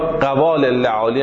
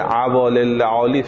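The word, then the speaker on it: عوالي اللئالي: خب عوال اللعالی یا قوال اللعالی هم بهش میگن. مرحوم عاشق آقا بزرگ تهرانی گفته عوال اللعالی صحیح تلفظ کتاب، نوشته شیخ ابن ابی جنبور احسائی، محدث و فقیه و متکلم شیعه در قرن نهم هجری.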